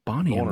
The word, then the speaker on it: Boney M: B-O-N-E-Y-M. But it's pronounced Bonnie M.